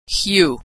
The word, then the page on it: hue: American English